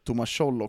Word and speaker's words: Thomas Scholl: Jag hör mycket bra om eh, Thomas Scholl också i målet där, som ska in eh, Klaga inte på det uttalet, för det var klockrent.